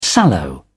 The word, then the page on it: sallow: British English